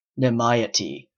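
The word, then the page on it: nimiety: Canadian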